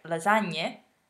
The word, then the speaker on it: lasagne: So when you see a word written with G plus N, like lasagne, you know that you need to pronounce the smiley N.